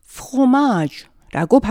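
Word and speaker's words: fromage: 그렇게 추측하기 쉽지만 프랑스어로 치즈는 이들과는 전혀 다른 e, F-R-O-M-A-G-E, fromage라고 발음합니다.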